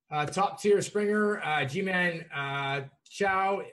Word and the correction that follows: Choi: Uh, Ji-Man Choi in the mid tier and Rougned Odor for value.